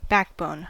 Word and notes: backbone: American English

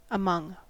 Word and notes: among: American English

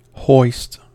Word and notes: hoist: American English

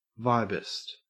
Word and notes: vibist: Australian English

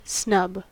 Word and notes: snub: American English

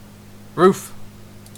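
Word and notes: roof: Canadian English

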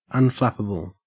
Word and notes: unflappable: Australian English

